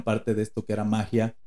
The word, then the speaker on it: Mahya: M-A-H-Y-A, magia.